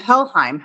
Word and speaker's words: Helheim: I'm going to ask you to pronounce it for me again. – Brides of Helheim. How is this hard? – Well, I think Heilheim or something like that.